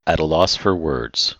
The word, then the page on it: at a loss for words: idiom